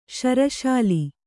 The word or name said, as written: ಶ್ರೀಶೈಲ